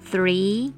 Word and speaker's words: three: 数字三 three three t h r e e，比较不容易的地方是 t h 的发音 f 以及 r 的发音 r 所构成的子音群。